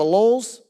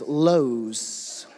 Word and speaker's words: Lowe's: We didn't have a Lowe's. We didn't have any of that. Lowe's, that's how they say it in the country. Lowe, my dad says, hey, we're going down to Lowe's. Dad, it's Lowe's.